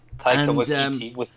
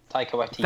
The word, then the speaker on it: Taika Waititi: Taika Waititi. – I didn't say because I knew I'd butcher the heck out of the name. – Taika Waititi.